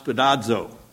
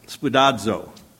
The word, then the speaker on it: spoudazo: And the way it's spelled in the English is S-P-O-U-D-A-Z-O. It's pronounced spudazo, spudazo.